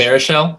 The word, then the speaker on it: Baruchel: Judd Apatow did another one, but it was about college, and Charlie Hunnam was in that with Seth Rogen, um, Jay Bertrell. Bertrell. I can never get his name right, and it's it's oh, just Baruchel? Yeah, Baruchel, there name.